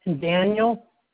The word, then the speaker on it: Daniel: Daniel, D-A-N-I-E-L, and last name is Cardenas, C-A-R-D-E-N-A-S.